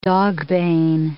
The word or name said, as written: dogbane